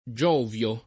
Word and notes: Giovio: lingua italiana

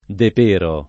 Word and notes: De Pero: italiana

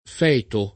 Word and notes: feto: lingua italiana

